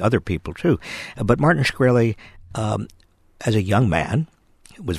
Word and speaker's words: Shkreli: You, in your first chapter of the book, talk about Martin Shkreli. I'm not sure if I'm pronouncing it. – Shkreli, yes.